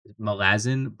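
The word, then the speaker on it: Malazan: Melazin, Book of the Fallen.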